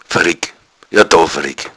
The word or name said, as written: varregg